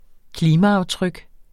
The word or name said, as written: klimaaftryk